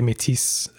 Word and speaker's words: Métis: I know you often say Métis. That's kind of, I think that's probably because you have more French than I do. Mm. Honestly, it's a more common like French Canadian pronunciation.